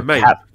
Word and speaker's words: MAME: Is it, how do you pronounce it? Is it me? MAME? Like your MAME. The arcade emulator.